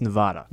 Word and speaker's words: Nevada: Nevada, am I saying that right? Nevada? – I think it's Nevada.